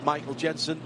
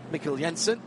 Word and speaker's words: Mikkel Jensen: And that puts Michael Jensen, and that is how you say it, because it's Michael Jensen, not Mikkel Jensen.